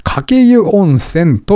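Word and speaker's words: 鹿教湯温泉: えー、長野の上田市の温泉地、えー、鹿に教える湯温泉と書いて、かけ湯温泉と読みます。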